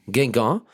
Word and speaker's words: Guingamp: En die zei, ik heb woensdag uitvoerig gesproken in Guingamp. Zeg ik dat goed? – Guingamp.